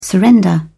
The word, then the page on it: surrender: British English